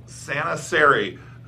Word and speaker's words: Saana Saare: Her name is Saana Saare. Did I say that right or close? – Saare rhymes with fairy. – Saana Saare, who is going to tell us all about the wonderful things she does in High Springs.